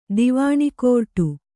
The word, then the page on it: ದಿವಾಣಿಕೋರ್ಟು: kannada